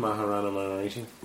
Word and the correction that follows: Ramana Maharshi: Ramana. Maharshi.